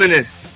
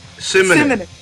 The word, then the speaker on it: Simonon: Simonon. Simonon.